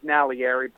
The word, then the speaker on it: Naglieri: It's Italian, so the G is silent, which is Naglieri, but that's pretty good.